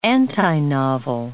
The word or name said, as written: antinovel